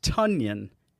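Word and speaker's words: Tonyan: It's pronounced Tunyon like Paul Bunyan.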